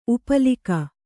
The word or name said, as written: ಉಪ್ಪಲಿಕ